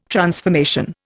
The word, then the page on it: transformation: Indian